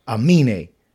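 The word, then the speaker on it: Aminé: I think, say his name for me. He dropped the album. Amine. – Okay. – That's his name, right? – Mm-hmm. – I said it right.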